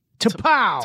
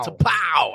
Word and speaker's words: T'Pau: T'Pau. – T'Pau.